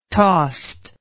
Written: tost

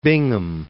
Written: Bingham

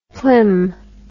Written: plim

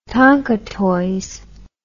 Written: tonka toys

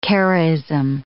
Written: Karaism

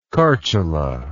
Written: chartula

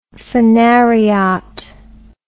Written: phanariot

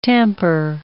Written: tamper

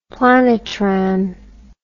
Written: planetran

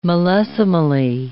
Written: millesimally

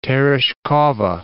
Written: Tereshkova

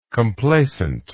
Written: complacently

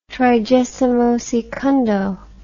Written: trigesimosecundo

